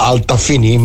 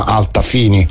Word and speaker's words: Altafini: come non era Altafini ma Altafini, cioè José Altafini, cognome italiano.